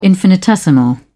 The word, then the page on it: infinitesimal: British English